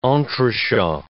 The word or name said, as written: entrechat